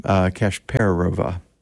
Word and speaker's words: Kasperova: I just murdered her name, but Kasperova. No, the emphasis is on the per, Kasperova.